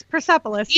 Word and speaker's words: Persepolis: Persepolis? – No, that's right. – Yeah. – Oh, yay. – Yeah, it's Persepolis.